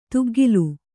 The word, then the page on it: ತುಗ್ಗಿಲ್: kannada; tuggil